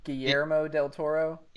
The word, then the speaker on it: Guillermo del Toro: How do you say his name? Gomiro? – Yeah, is it what is it? – Guillermo? – Guillermo del Toro.